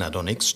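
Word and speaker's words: Adonxs: Das X in Adonis steht im Übrigen für seine Androgynität. Deswegen wird das X auch wie ein I ausgesprochen.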